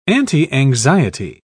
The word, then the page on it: antianxiety: British English